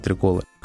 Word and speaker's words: треколы: просто сказал, Да, да, да, ударение, да, правильно говорить треколы.